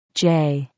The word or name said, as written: j